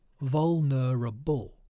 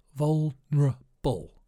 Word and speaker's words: vulnerable: I quite like the pronunciation of this word because the middle syllables merge together. There are four syllables, vulnerable, but it sounds more like vulnerable, vulnerable.